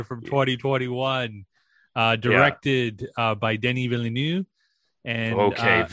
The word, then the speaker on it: Villeneuve: There's a V on the end, dog. – Yeah, but it's French, dog. I didn't know that. – So Villeneuve. There's words. There's letters that they do pronounce, and words that it. Fuck. – There's letters that they do pronounce, and other ones that they don't. – Yes, and V is one of the ones that do get pronounced at all. – V is always pronounced.